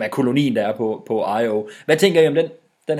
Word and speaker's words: Io: som hedder Io, inklusiv hvordan det skal udtales. Det udtales I-O.